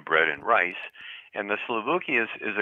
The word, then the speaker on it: souvlaki: Yeah, it's souvlaki, which is basically a shish kebab plate, okay, is what it is.